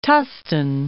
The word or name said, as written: Tustin